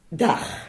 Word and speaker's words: dag: Dag. – Dag, ça s'écrit D-A-G. – Ah oui, mais le G se prononce re, un petit peu comme ouais.